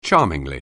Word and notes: charmingly: anglais

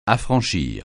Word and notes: affranchir: French

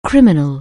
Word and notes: criminal: anglais

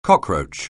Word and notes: cockroach: anglais

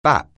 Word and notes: bap: anglais

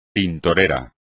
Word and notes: tintorera: espagnol

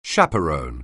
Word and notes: chaperon(e): anglais

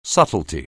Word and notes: subtlety: anglais

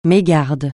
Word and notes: mégarde: francais

